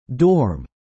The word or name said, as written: dorm